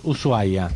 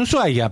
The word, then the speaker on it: Ushuaia: Igual son creencias, porque viste, a veces de golpe entras, Ushuaia, sí. Que me encanta que se escriba SH, pero no se pronuncia, la H es muda.